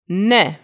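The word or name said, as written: ναι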